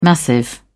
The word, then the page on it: massive: British English